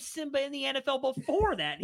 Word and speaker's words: Nsimba: I don't even know how to say this dude's name, but I'm pretty sure this is right. Simba it's spelled N S I M B A, so I'm assuming it's Simba and the N is silent.